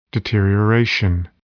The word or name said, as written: deterioration